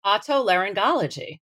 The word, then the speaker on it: otolaryngology: And I know how to say this, otolaryngology.